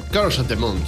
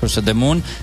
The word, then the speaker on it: Curse of the Moon: Bueno, bueno, vamos a este breve análisis del Bloodstain Curse of the Moon. ¿Lo he dicho bien? Eh, Curse of the Moon, sí, así. Curse of the Moon.